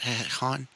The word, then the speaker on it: Hahn: Fucking, I've always liked Catherine Hahn. Is that how you say it? Hahn? Catherine? – Yeah.